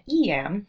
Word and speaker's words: Eyam: Now, people have talked to me about Eyam, pronouncing it E-M, but also I-M. Recently, I read an interesting article that insisted it should be pronounced Eyam. But those who have been there tend to call it Eyam, so that's what I am using.